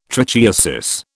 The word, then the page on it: trichiasis: British English